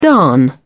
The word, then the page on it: darn: British English